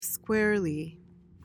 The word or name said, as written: squarely